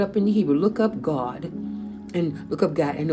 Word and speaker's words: Gad: Oh, now, but people using the English pronunciation say Gad, but the correct pronunciation is God. You know where they say, believe in God, you would spell it made G-A-W-D, God.